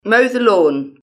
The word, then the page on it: mow the lawn: British English